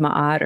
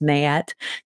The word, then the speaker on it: Ma'at: And if your heart is as light as the feather of Ma'at, or some pronounced Mayat, then you can pass through to the fields of reeds, which is like the next life, right?